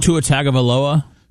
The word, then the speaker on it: Tua Tagovailoa: Tua Tagovailoa? – Tua Tagovailoa.